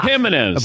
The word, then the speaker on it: Jimenez: It starts with a J, but pronounce it with he. Yeah. – yeah, that's right. Ubaldo yeah. Jimenez.